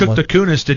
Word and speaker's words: Kunis: Kunis is another dirty sounding name. – But that's the right way to say it.